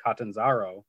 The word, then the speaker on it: Catanzaro: So that's why a tryout that they could be bringing in Chandler Catanzaro, right? Is that how you pronounce it? – Sure.